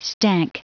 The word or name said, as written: stank